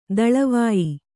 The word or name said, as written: ದಳವಾಯಿ